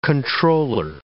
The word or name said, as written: controller